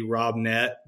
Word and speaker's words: Robnett: Your Instagram name is at Shannon Ray Robnett.